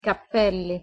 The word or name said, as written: cappelli